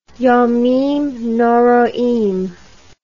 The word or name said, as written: yomim noroim